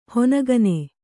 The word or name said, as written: ಹನಿಗಣ್ಣು